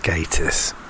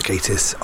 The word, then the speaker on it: Gatiss: But in actual fact, it is pronounced Gatiss, from the man himself, Mark Gatiss, on Twitter, at The Daily Crumb. It is pronounced Gatiss and no other way. Just think gay.